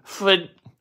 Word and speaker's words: Pfaadt: Justin Steele on the bump in Arizona against Justin Fat, P-F-A-A-D-T. Justin is one foot, that's how we'll pronounce it. Justin Foot.